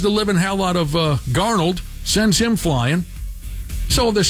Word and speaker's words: Darnold: First off, he put a late hit on Ducks quarterback Sam Darnold. How do you say it? – Darnold.